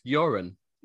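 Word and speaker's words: Göran: Sven Goran Eriksson was... – I think you'll find it's pronounced Joran.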